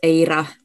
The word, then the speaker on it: eira: And for example, my favorite word in Welsh is eira, which means snow. And because we Finns, we like also roll the R's.